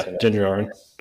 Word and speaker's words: Din Djarin: Din Djarin.